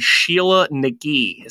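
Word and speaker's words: Sheela na gig: And the female version with the exaggerated vulva, as they call it, is a pagan symbol called Sheila Nagi, is how it's pronounced.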